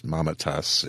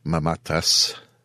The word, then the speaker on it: Mamatas: It's Mamatas in HWA circles. But I do believe the proper Greek pronunciation is Mamatas.